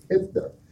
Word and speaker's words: HYPFTIR: We came up with another moniker, which is spelled HYPFTIR, HIPTIR.